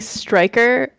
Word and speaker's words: Stryker: Okay, so my name is Allie Stryker. Both are spelled with a Y in case anyone wants to know.